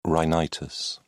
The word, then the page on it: rhinitis: British English